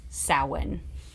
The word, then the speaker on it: Samhain: It's pronounced Samhain. – That's intense. – Yes. It's because it's Irish. When you put an H next to another consonant, it makes a totally different sound.